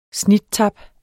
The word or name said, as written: snittap